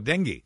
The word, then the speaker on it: dengue: How do you pronounce this one? D-E-N-G-U-I, denju? – Oh, dengue. Dengue fever.